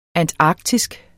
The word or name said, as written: antarktisk